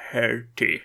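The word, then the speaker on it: Dougherty: Doherty. Korrekt uttal, fast lite snabbare var det.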